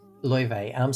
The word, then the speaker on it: Laufey: Loewe. So it's spelled Laufey.